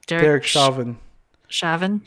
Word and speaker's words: Chauvin: Derek Chauvin.